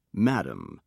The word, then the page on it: madame: British English